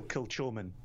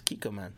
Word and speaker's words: Kilchoman: But still, Kilchoman. – It's Kikoman. It's like the soy sauce.